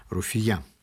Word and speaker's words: Руфия: Так стоит ударение у Марата. Руфия. Вспоминаю, как летом 67-го года в городе появились огромные портреты моей возлюбленной.